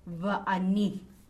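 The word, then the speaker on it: va'ani (ואני): Okay, so when you want to say and I, okay, it's va'ani, and I, only when it is aleph, okay.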